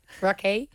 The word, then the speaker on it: Rakei: Rakei. – Rakei.